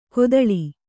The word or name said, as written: ಕೊತ್ತಳಿ